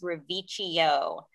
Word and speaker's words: Reviccio: But it's actually, the pronunciation is Reviccio with a CH sound.